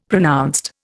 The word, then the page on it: pronounced: Indian